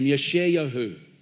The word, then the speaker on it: Yeshayahu: His name is pronounced as Yeshayahu. That's how we pronounce his name, Yeshayahu. Or Yeshayah in the original Hebrew text.